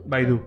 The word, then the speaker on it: Baidu: El Google de, China, que es Baidu, Baidu, Baidu.